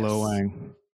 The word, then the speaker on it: Lo Wang: Lo Wang. – Yep, Lo Wang. It's awesome, dude. It's so funny. – All right, I want to pick it up just for the guy's name is Lo Wang.